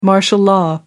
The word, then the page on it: martial law: American English